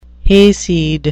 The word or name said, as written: hayseed